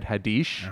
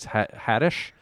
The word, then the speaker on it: Haddish: I think it is pronounced I thought I said Haddish. – Uh-huh. – And I was told it's Haddish.